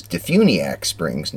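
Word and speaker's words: DeFuniak Springs: And Patty, I learned when we checked in here on Monday that it is Dufuniak Springs, not Dufuniak Springs, as we had been pronouncing it.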